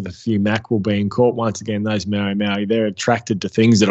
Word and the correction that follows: mahi mahi: It's mahi mahi. Mahi mahi, mahi mahi. Dolphin fish. – Dolphin fish. Dorado.